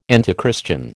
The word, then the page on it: antichristian: American English